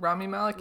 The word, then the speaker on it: Rami Malek: Rami Malek? Is that how you pronounce it? – Rami Malek, Rami Malek.